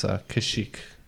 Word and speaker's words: Kashyyyk: But in common tongue, it's Kashyyyk.